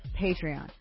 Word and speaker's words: Patreon: Far-left extremists are apparently raising money on, how do you say this, Patreon to inspire, Patreon. To inspire insurrection. Oh, my goodness. These apparently little skinny-armed resistance people, they want to have an armed insurrection, so they're raising money on Patreon.